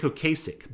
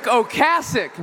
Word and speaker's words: Ocasek: Rick Ocasek, not Ocasek. You're all saying it wrong.